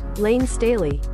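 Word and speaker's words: Layne Staley: Yes, it is Lane Staley.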